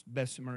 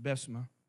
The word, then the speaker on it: Bessemer: except it wasn't pronounced Bessemer, it's pronounced Bessemer.